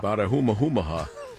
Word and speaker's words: Bartiromo: Bartiromo.